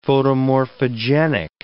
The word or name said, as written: photomorphogenic